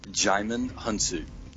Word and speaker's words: Djimon Hounsou: Yeah, Djimon Hounsou.